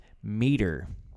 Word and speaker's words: meater: The word is meter. I think it's pronounced like a meter on the street. – Is it spelled differently. – Oh, okay. It is spelled M-E-A-T-E-R, meter.